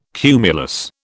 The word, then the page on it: cumulus: British English